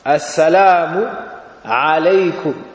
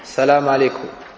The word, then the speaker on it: Assalamu alaykum: Donc, tu dis Assalamu avec Alif Lam. Assalamu alaykum. Tu dis pas Salam alaykum, comme ça. Aussi, il y a un Aïn dans le salam. Assalamu alaykum. Alaykum. Pas Salam alaykum. Non, alaykum.